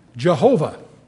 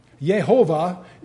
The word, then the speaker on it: Jehovah: Many of us are familiar with the old German Jehovah because we mispronounce it. Yehovah is another conjecture as to how it was pronounced. And in the German, you got the J for the Yehovah, and it's become Jehovah in many of our songs.